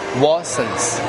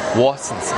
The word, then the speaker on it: Watsons: 叫 Watsons, Watsons，那个 T 不读不发音啊，Watsons, Watsons，我这说个面膜了。